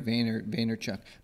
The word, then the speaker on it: Vaynerchuk: Vaynerchuk? – Gary Vaynerchuk.